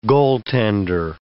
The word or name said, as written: goaltender